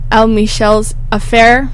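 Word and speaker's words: El Michels Affair: Ahead of that was East Side by El Michel's Affair. That's how you say it? – That's how you say it.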